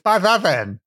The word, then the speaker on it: Ysbaddaden: Ysbaddaden. Is that well, so? Like, that's how you say it, right, Jenny? – Pretty much there, but double D is pronounced. – Oh, so it's by that then.